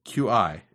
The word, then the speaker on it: Qi: That's what QI is pronounced Qi.